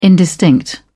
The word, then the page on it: indistinct: British English